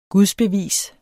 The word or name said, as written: gudsbevis